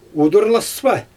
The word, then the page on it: udurlaspa: Xyzyl